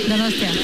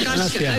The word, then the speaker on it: Donostia: Donostia. – Donostia.